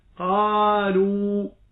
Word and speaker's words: قالوا: عندما تنظر مثلا إلى كلمة قالوا، هناك ألف بعد الواو، هذه الألف وضع عليها سكون مستدير، والمعنى أن هذه الألف لا تنطق حال الوصل ولا تنطق حال الوقف.